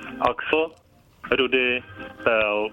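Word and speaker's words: Axel Rudi Pell: Axel, Rudy, Pel.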